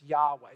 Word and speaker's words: Yahweh: Others say, well, there is no J sound in Hebrew, so we're going to stick with the Yahweh. That's how they see it. In fact, most scholars say that is the best way to pronounce these four consonants, Yahweh.